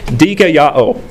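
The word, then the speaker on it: dikaioō: And it's the Greek word dikaiō. I'm going to spell it. So, dikaiō. D-I-K-A-I-O-O. Dikaiō.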